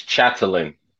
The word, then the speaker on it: Chatelain: It's Chatelain.